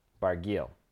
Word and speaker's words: Barguil: People that appear to be in the know pronounce it Barguil. – Barguil. Okay. – They say the L.